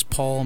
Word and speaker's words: Paul: Paul and Puzzle were a little bit close when I wrote them for my gamer tag.